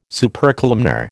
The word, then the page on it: Supercolumnar: American English